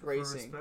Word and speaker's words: Racing: Racing, I'm gonna say it in the way the Argentinians say it. – It's Racing. It's spelled Racing.